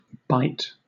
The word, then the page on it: bight: British English